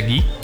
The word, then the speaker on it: Guy: Esto lo leí, de hecho, de parte de la sociedad del espectáculo de se escribe Guy, pero se pronuncia Guy.